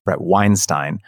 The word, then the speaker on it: Weinstein: I realized I mispronounced his name the first time. It's kind of a force of habit. Brett Weinstein.